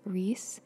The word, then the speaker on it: Reis: Okay, I just checked an NPR snippet for the pronunciation of Rachel's name, and they say it as Reese, so that's how I will say it.